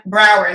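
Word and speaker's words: Braugher: And Andre Braher. Brower, thank you.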